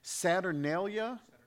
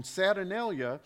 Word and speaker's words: Saturnalia: Along with that, there were Roman holidays known as the Saturnalia.